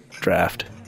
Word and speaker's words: draught: I pour glasses for my three companions and then one for myself and drink it in a single draft. Draught. How do you pronounce that word? Is it draught or draft?" – "Draft."